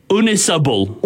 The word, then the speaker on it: Ynysybwl: Yunisbull. – Yunisbull. – Yunisbull. Yunisibul. – Honestly? – Yunisibul? – Very close. – Is it Yunisibul? – It's pronounced Unisibul.